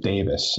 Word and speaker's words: Davies: Their last names were the Welsh spelling D-A-V-I-E-S that most Americans call Davies. but the pronunciation is, as you correctly mentioned, Davis.